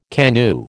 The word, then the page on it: KANU: American English